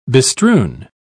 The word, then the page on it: bestrewn: British English